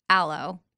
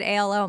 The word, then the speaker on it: Alo: It's like a new way to try to add some hydration, which I, when you first said ALO, I'm like, wait, I'm wearing ALO, out aloe, I think is how you say it, yoga pants.